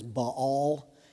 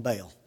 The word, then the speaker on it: Baal: And probably more correctly pronounced as Baal, but in the south we call him Baal.